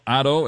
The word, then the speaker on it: Atto: And it's spelled A-T-T-O, Otto, and that's the pronunciation.